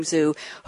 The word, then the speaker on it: Zhu: but he told me he pronounces his last name Zhu,